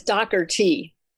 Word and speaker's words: Dougherty: It's Dougherty.